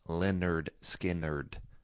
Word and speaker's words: Lynyrd Skynyrd: That is Things Going On from 1973's pronounced Lynyrd Skynyrd, which is a fun you know, debut album name to make sure that people know how to say the name of your band.